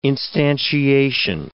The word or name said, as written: instantiation